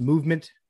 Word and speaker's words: MVMT: MVMT, pronounced Movement, grew into one of the fastest growing watch brands, shipping to over 160 countries across the globe.